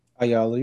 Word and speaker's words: Ayala: Ayala, how you say it? – Ayala, you were right.